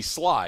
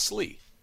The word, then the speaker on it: Sli: Because S-L-I could be Sly, and it's Slee.